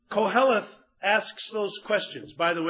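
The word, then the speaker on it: Koheleth (קהלת): The Hebrew word, and by the way, I made a point of asking two Orthodox Hebrews to make sure I was pronouncing this right. The Hebrew is Koheleth.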